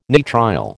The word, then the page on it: nitrile: American English